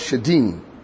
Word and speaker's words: shedim: The word shedim, S-H-E-D-I-M. Let's turn there quickly. So eventually you see that 32 and 17. The sacrifice unto devils, okay? That's the word demons. And it's pronounced or is spelled shedim.